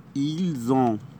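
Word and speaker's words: ils ont: هنقوله ايل زون، ايل زون يعني هم لديهم، برضو هننطق الاس زد عشان جه بعديها حرف متحرك.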